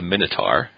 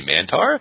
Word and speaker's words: Minotaur: If you watch his debut match, you can hear Tony Chimmel introduce him as the Minotaur, and then Lawler pipes in with, did he say Mantar? And no, he clearly did not. So I don't know what was wrong with Minotaur, just that extra syllable.